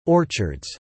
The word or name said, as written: orchards